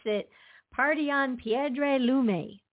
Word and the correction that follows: Piedra Lumbre: All right. Lumbre. Sounds good to me. – Party on Piedra Lumbre.